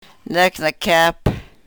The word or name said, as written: nek ne-kep